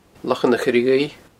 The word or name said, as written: Lochan a' Choire Dhuibh